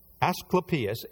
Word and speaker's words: Asclepius: Asclepius, A-S-C-L-E-P-I-U-S, Asclepius.